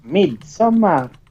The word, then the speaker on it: Midsommar: It's pronounced Midsommar.